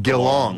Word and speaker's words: Geelong: And what did you, how is it pronounced? – It's Geelong. – Geelong. – Geelong.